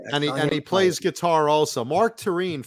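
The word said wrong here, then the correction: Torien: for those that don't, or Torian, I believe his name is pronounced.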